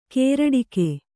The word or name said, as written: ಕೇರಡಿಕೆ